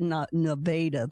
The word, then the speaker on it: Nevada: Is that how you say it, or is it Nevada there? – Nevada.